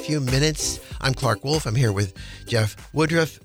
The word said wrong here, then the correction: Woodworth: Did I say that right? – Woodworth. Close enough. – Woodworth. – I've been called worse at the grocery store. Don't you worry. – Exactly. A Woodworth is, you know, like Woolworths, like the counter.